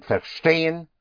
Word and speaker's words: verstehen: Ver is spelled V-E-R and the V always has a sound of an F. Verstehen.